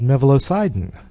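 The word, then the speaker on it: mevalocidin: So I'd like to spend a little time talking in some detail about one of your success stories, the natural herbicide called mevalosidin. And I think I pronounced that correctly right off the bat. – Yes, you did.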